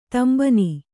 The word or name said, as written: ತಾಂಬಾಣ